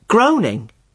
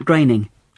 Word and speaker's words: Groening: I always thought it was pronounced groaning. – Well, I did too, for a long time. But no, it isn't. It rhymes with raining. Matt Groening.